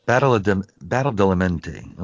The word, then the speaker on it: Badalamenti: Angelo Badalamenti? – Badalamenti. – Badalamenti.